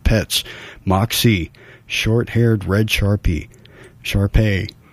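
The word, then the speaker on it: Shar-Pei: All right, we're looking for Moxie Lost, a short-haired red Sharpie mix. Yeah, Sharpay. Excuse my pronunciation there.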